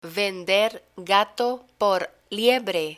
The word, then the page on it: vender gato por liebre: Spanish